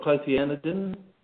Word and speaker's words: clothianidin: Well, Tom, it's do you call it clothianidin? – Yes, that's how I pronounce it.